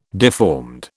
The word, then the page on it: deformed: British English